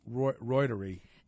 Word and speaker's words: reuteri: Yeah, the probiotic Al Ruderi, which we're going to see that become, actually it's pronounced Reuteri. – It's spelled R-E-U-T-E-R-I.